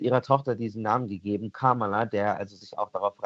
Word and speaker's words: Kamala: It's Kamala. Just, Kamala. Yeah, just think of like a comma and add a la.